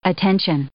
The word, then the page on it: attention: noun